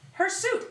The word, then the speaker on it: hirsute: Hairsuit, really? – Hirsute. H-I-R-S-U-T-E. Hirsute. – Oh, okay. It's just spelled... It's pronounced adjective. – Like H-A-I-R-S-U-I-T. – Kind of.